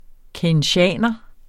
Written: keynesianer

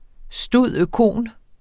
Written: stud.oecon.

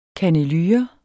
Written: kannelure

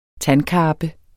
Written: TANDKARPE

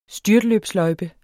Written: STYRT

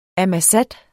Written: ammassat

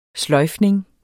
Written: sløjfning

